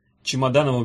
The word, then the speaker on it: Чемоданову: Чемоданову безо всякого повода стало весело, ударение на «о».